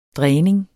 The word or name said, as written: dræning